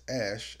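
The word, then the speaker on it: Æ: X-A-E, which is... pronounced Ash, as he said on Joe Budden, because he went on Joe Rogan shit the other day.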